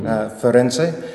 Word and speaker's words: Firenze: We've been to, I'm going to be snooty and use the Italian pronunciation, Firenze, Positano, and Roma, Roma, due times, twice.